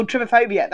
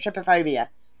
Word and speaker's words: Trypophobia: And it plays on that, I can never pronounce it, but you know the, is it Mixer, Pelixer, Mixer, Mixer, Trypophobia? – Oh, Trypophobia, that's it, Trypophobia.